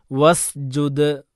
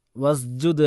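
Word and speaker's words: വസ്ജുദ് (واسجد): വസ്ജുദ് വസ്ജുദ് വസ്ജുദ് അല്ല വസ് സീന് തന്നെ പറയണം വസ്ജുദ്.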